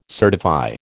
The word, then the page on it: certify: American English